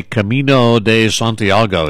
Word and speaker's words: Camino de Santiago: When I say Camino de Santiago, is that pretty close to the right pronunciation? – I think you really nailed it. It's pretty good.